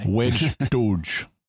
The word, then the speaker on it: wej Duj: I probably got that wrong. I'm sorry for my pronunciation. – Pretty close. – Okay. – Wedge Douche. – Douche. – Yeah. – Okay. – The emphatic D is like the English D, but you pull your tongue farther back in your throat. – Okay. – Or in your mouth.